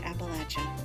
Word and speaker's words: Appalachia: and I'm hoping that you all understand that people from Appalachia say Appalachia, not Appalachia. And if you take nothing else away from this, which I sincerely hope isn't the case, it is that it is pronounced Appalachia. Thanks, you guys, for listening.